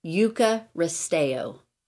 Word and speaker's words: Eucharisteo: But it's Eucharisteo.